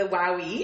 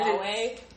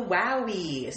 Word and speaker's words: Huawei: But since I went to TELUS and got it, the girl was like, it's called the Wowie. So now that's how I say it. And it's not how you say it. – It's not Wowie.